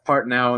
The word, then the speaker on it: Partnow: Yeah, it's at Seth Partnow, and my last name is spelled P-A-R-T-N-O-W.